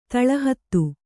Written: ತಳಹತ್ತು

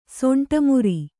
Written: ಸಿಂಟೆಮರ

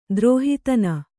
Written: ದ್ರೋಹಿತನ

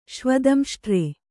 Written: ಶ್ವದಂಷ್ಟ್ರೆ

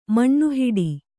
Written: ಮಣ್ಣುಹಿಡಿ

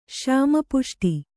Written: ಶ್ಯಾಮಪುಷ್ಟಿ

